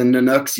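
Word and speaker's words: Nanook: I spent a little bit of time, obviously, with the Nanooks. You said that properly. That's an Inuit name for polar bear.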